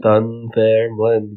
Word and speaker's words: Dunfermline: Dunfermline, yes. Pronounced just like that.